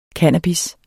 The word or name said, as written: cannabis